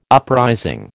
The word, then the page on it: uprising: American English